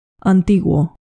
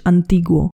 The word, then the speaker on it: antiguo: Antiguo. Tutaj trochę inna wymowa. Antyczny, ale myślę, że można się domyśleć. Antiguo.